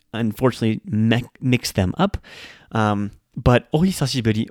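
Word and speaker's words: ohisashiburi (お久しぶり): But the word is ohisashiburi, ohisashiburi. Now, this is pronounced a little bit slower as ohisashiburi. It's the he sound. Often, this is mispronounced as oi or oya for some very, very strange reason that I can't quite wrap my head around.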